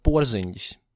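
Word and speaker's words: Porziņģis: All of Latvia are New York Knicks fans by now because of Porzingis, and that's how you properly pronounce his surname.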